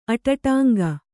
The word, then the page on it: ಅಡ್ಡಂಕೆ: aḍḍaŋke